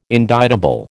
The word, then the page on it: indictable: American English